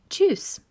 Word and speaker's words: Tschüss: Okay, hallo, hello, servus, that means hello, and tschüss, it's spelled two different ways. You can spell it with two S's on the end, or just one S. is pronounced the same way.